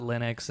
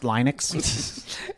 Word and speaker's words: Linux: So, you know, we'll go through kind of how they did that stuff and how they, you know, fought Linux and all these different things. – And when you look at that... – It's pronounced Linux.